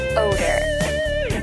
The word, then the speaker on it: odor: The word order has this O sound, followed by a tiny R sound, like or, while odor contains this O sound. Let me demonstrate the pronunciation of these words.